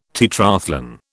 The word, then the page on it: tetrathlon: British English